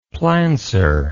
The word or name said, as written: plancer